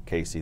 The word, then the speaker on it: Casey: Casey, they would call me Cassie, which I've been called that, making fun of.